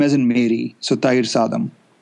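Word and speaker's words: Thayir Saadam: Thair Sadam,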